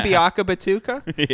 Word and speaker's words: Biakabatuka: Tim Biakabatuka? – Yeah.